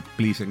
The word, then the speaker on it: Plis: Esta canción que traemos, llamada Please, se escribe P-L-I-S, pero como decir Please en inglés, por favor, fue compuesta por Camilo.